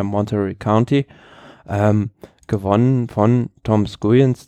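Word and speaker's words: Skujiņš: Und am nächsten Tag, Entschuldigung, am nächsten Tag war es dann Etappe Nummer 3 natürlich, die gewonnen wurde von, wie spricht man denn aus? Skujens? – Tom Skujens, ja.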